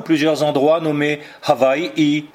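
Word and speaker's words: Hawaii (Hawaiʻi): et que les Hawaïens eux-mêmes prononcent Hawaii.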